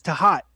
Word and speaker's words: Thoth: That's Thoth. – Oh, yeah. Snaps. – So, but the proper pronunciation I learned when I went to Egypt was Tahat.